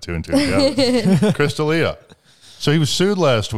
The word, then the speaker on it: D'Elia: Chris, I think his last name is pronounced like D'Elia.